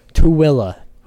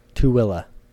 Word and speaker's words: Tooele: I'm going to up north to Thule. Okay. Tooele. Who spelled Tooele? – How do you spell it? – T-O-O-E-L-E. Tooele. Thule. – Yeah, that Thule. – Yeah, it's Tooele. Who the hell dropped out of third grade and decided to name cities?